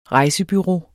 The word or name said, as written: rejsebureau